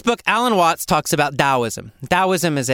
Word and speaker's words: Taoism: Why didn't we just spell it with a D? I don't know. It comes from, you know, Chinese words, so I guess they wrote it, they heard it wrong, and I don't know. Anyway, it's pronounced Taoism. And the book is called Taoism, Way Beyond Seeking.